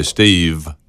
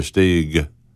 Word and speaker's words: Steig: A lot of people know him because Rock Hill, but S-T-E-I-G. Which I love when he introduces himself to people in there. Number one, they say Steve, which it took me a while to get his name right, too. But you will never meet another Stig. But back in the 80s, he and his wife were a group called Izzy's Kids.